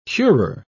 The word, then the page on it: curer: British English